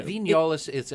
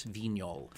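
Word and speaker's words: Vignoles: Vignol. Although if you want to call it Vignolis, as I've heard it called a lot, you know, that's fine.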